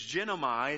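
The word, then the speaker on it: genomai (γίνομαι): It's genomai. It's how you pronounce it. Genomai.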